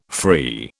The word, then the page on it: free: British English